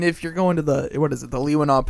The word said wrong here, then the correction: Keweenaw: Peninsula, say that right? Keweenaw. Sorry.